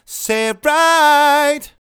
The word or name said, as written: Say It Right